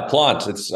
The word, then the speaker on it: Plante: Yeah, Plante. I get Plante a lot, so it was refreshing to hear the proper pronunciation there.